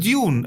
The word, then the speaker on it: Dune: Nein, es heißt Dune. Geh auf Google und lass es aussprechen. Die sagt Dune.